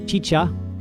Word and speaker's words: XIXA: The band name is XIXA, which I'm going to pronounce Chicha, and their song Choloin Squintly, which I've played a number of times in the past here on KDVS.